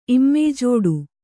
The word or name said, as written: ಇಮ್ಮೆಯ್ಜೋಡು